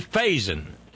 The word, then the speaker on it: Faison: And, well, enter representing Jeremy Faison, F-A-I-S-O-N. You know, probably piss him off if you pronounced it kind of French, like Jeremy Faison. Don't talk it like that!